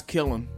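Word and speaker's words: Killeen: K-I-L-N? – Isn't that... Well... I don't know how they pronounce it down there, but isn't that traditionally pronounced kill? Like when you have a clay thing and you put it in the kill, like that like that special oven for like pottery and shit to make it hard. – Are you pronouncing the N at the end? – No. – Oh, it's just kill? – Yeah. – Nah, it's killing.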